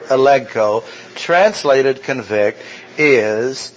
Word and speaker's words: elegko: The word convict is the verb elegko. E-L-E-G-K-O, elegko. Elegko, accent on the second syllable, elegko.